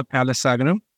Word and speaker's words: Palasagaram: Yeah, it's Jithendra Palasagaram.